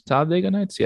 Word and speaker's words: Talladega Nights: Talladega Nights? Yeah, that's how you pronounce it.